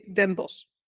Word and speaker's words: Den Bosch: They just say Den Bosch. – Den Bosch.